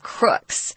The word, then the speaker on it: crook: Sometimes we get angry at our politicians and we call them a bunch of crooks. They're just kind of dishonest. Here, they really are criminals, so we can call them crooks. It's pronounced like book, crook.